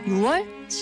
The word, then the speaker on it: 6월: 6월과 10월이 아니라 6월과 10월인 이유 바로 발음조 현상 때문인데요. 발음하기 쉽거나 듣기 좋도록 소리가 바뀌는 음운 현상을 말합니다. 이렇게 달라진 소리가 표준어로 굳어졌기 때문에 발음할 때도 표기할 때도 6월, 10월이 맞습니다.